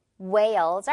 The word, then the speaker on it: whale: 여기서 이 huge 단어에서는 H가 제대로 발음이 나네요. 하지만 고래, whale에서는 빼줄게요. Whales are huge.